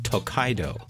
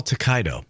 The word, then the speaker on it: Tokaido: How do you spell Tokaido? – Oh, you spell it T-O-K-A-I-D-O.